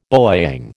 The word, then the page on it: bowyang: American English